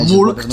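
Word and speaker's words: Mülk: To nie jest Milk, raczej się nie wymawia Milk. Tak. Milk. – Milk. Czyli Mulk, tak? Takim, Milk. Z takim umlautem trochę.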